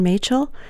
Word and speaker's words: Machel: My name is Sharon Machel. The last name is M-A-C-H-E-L. Rhymes with Rachel.